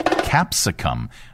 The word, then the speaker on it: capsicum: It was later in the 16th century when people began using pepper to also mean the New World capsaicin? Is that how you pronounce that? Goodness gracious! How did I miss this? Pronounce Capsicum.